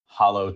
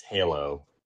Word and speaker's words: Halo: And there were a few sequels: Hollow 2, Hollow 3, Hollow 4, Hollow 3 ODST. Oh, I've been told by my producer I'm mispronouncing it. It's Halo.